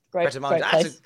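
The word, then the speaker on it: Pret A Manger: Right, I'm not sure if I'm pronouncing this right, but Pret-a-Manger. Pret-a-Manger.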